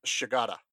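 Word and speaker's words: Shigeta: So they hired James Shigata. – You got his name right.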